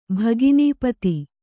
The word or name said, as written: ಭಗಿನೀಪತಿ